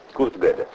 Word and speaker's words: Kurt Gödel: Penso sempre a voi che prendiate appunti e che magari non sapete il tedesco e quindi non sapete che la O con i due puntini sopra si pronuncia Kurt Gödel. Il signor Kurt Gödel